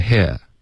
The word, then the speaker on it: here: OK, 所以比如 here 这一个字，如果我们要用一个英式的发音，它的发音是 here, 所以它是一个 a 滑到一个 a, 这是属于一个双元音，这是一个双元音，所以中间不会把它拆分开成两个音节然后再加一个 y 进去。